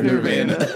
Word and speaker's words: Nirvana: So Nirvana. It's pronounced Nirvana.